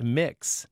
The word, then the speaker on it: Mx: And one seems a clear sign of the times, the use of MX, pronounced mix, as a form of address.